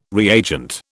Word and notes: reagent: British English

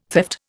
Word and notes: theft: Indian

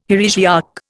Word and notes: heresiarch: Indian

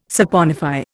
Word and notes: saponify: Indian

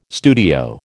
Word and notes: studio: American English

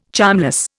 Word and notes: charmless: Indian

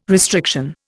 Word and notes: restriction: Indian

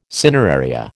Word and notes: cineraria: American English

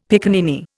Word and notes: piccaninny: Indian

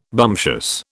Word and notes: bumptious: British English

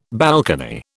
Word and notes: balcony: British English

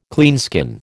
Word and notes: cleanskin: American English

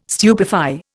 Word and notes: stupefy: Indian

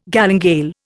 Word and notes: galingale: Indian

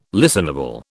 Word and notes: listenable: British English

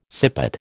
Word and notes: sippet: American English

